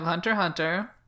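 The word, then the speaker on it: Hunter × Hunter: Okay, so next we have Hunter Hunter. – Greatest anime ever. – It's not, the X is not pronounced.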